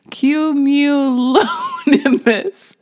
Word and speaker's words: cumulonimbus: Cumulonimbus. – You nailed it. – Was that right? – Yep, effortless. Rolls off the tongue. – Cumulonimbus.